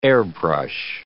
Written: airbrush

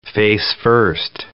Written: face-first

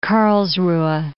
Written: Karlsruhe

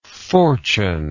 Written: fortuneless

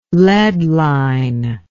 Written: lead line

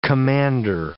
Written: commander

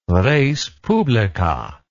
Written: respublica

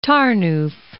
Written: Tarnow

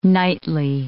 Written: knightly

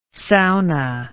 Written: Saona

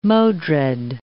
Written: Modred

